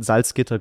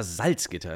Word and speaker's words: Salzgitter: Aber damals, als du, du bist in Salzgitter groß geworden. Alle oh. sagen immer Salzgitter, das heißt Salzgitter. Die Betonung ist auf dem Gitter.